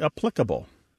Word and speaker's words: applicable: It's also words like applicable becoming applicable, formidable becoming formidable, And the famous one, which actually has a UK-US divide, although usually there is no US-UK divide.